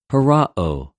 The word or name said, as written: ὁραω